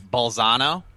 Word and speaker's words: Bolzano: So this brings us to 2007, when you signed with, I don't know if I'm pronouncing the Italian name right, Bolzano? – That's how I would pronounce it, yeah.